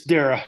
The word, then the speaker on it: Darrah: Did I say your last name right? Dara or Dara? – It's Dara. – Jeff Dara. I pronounce my A's.